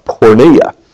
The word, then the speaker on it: porneia: Fornication is a broader word, and the word we're looking at right now in Greek is pronounced porneia.